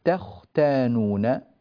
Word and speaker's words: تختانون: لاحظوا هنا عندنا التاء في كلمة تختانون، هذه التاء هنا يجب أن تعطى حقها من الترقيق، هذه التاء مرققة، بينما هذه الخاء هذه مفخمة وهذه التاء طبعا مرققة. فبالنسبة للتاء التي يقع فيها الإشكال عادة في النطق، هذه التاء التي عليها الدائرة لأنها متلوة بحرف الخاء المفخمة، فبعض الناس يقول تختانون، طا طا تختان، فتفخم معه التاء تصير طاء، وهذا طبعا خطأ لا يصح.